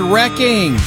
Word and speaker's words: wrecking: Text the word wrecking to 95819. W-R-E-C-K-I-N-G. The W is silent.